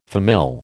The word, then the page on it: famille: American English